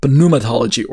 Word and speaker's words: pneumatology: ontology and pneumatology or pneumatology if you want to pronounce the p